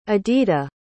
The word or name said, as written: adita